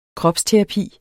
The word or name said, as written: kropsterapi